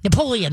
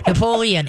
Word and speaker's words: Napoleon: One dog I got, they named him Neapolitan. Is that right? Napoleon. There we go. I knew I was saying that wrong. Napoleon.